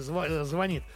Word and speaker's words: звонит: Нет, она, конечно, знает, что ударение в слове звонит падает на второй слог, но ничего не имеет против изменения этого правила.